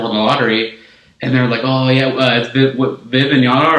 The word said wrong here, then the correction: Wembanyama: Wembanyama? – Yeah.